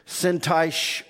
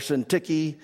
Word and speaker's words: Syntyche: Now I implore Euodia and I implore Syntyche, Syntyche or Syntyche. Either way, it's pronounced both ways that I've heard.